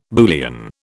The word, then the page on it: Boolean: British English